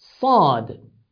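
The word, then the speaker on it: sad (صاد): This is the same S in English, but it is articulated with much emphasis. Instead of saying sad, you emphasize it stronger. Sad, sad, as if you have put something in your mouth and you pronounce it.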